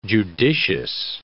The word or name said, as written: judicious